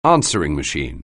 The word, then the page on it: answering machine: anglais